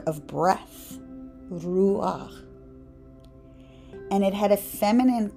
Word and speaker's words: Ruach: You would pronounce it R-U-A-C-H. Ruach.